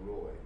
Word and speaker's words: Leroy: It was Leroy. – Okay, Leroy. – There was emphasis on the second syllable.